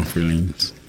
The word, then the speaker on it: Freelanes: I call them Freelanes. I mean, I wanted them to rhyme with pralines.